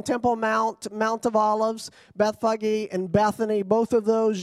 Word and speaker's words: Bethphage: Now when they came near to Jerusalem, to Bethphage, I looked that up because I wanted to say Bethphage, but it's Bethphage.